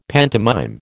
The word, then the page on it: pantomime: American English